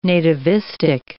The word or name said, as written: nativistic